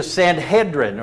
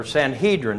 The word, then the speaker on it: Sanhedrin: We see the Sanhedrin, or Sanhedrin, depending on your pronunciation.